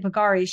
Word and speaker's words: Bulgari: Jen's fake jewelry included pieces claiming to be designed by Bulgari, is that how you say it? Bulgari, Chanel, yeah.